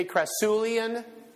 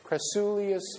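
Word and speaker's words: Crassulacean: Say Crassulean. Crassulean. Acid metabolism. I've been mispronouncing that for years.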